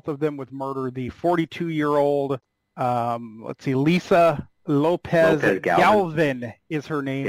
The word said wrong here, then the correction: Galvan: That was killed, Galvan. – The mother of two. – Galvan. – Now, the prosecutor, the prosecutor pronounced it Galvin.